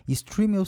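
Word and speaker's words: Stremio: Streamio se escreve S-T-R-E-M-I-O. S-T-R-E-M-I-O.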